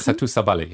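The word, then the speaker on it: Satou Sabally: Es gibt zum Beispiel diese deutsche schwarze Basketballerin Seju Sabali. Ich glaube, ich habe es hoffentlich richtig ausgesprochen. – Satu Sabali, ja.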